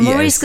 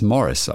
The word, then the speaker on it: Morris: Der ist schon Morris mit Doppel-R-I-S. – Dann hätte ich ja Maurice gesagt. – Morris. Naja, könnte ja sein, dass man das Maurice auf Französisch, dass die Amerikaner das Morris aussprechen. Wäre ja auch möglich. – Ich habe mich bemüht, es so deutsch auszusprechen. Morris. – Okay, ja, dann ist eindeutig. – M-O-R-R-I-S.